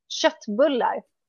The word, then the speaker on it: köttbullar: Köttbullar.